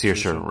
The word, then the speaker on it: Saoirse: I think it's Saoirse Ronan.